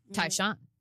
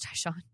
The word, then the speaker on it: Tai Shan: Tyshawn. – Tyshawn? – Mm-hmm. It's spelled T-A-I-S-H-A-N. – Interesting. – But Yes, his name was Tyshawn.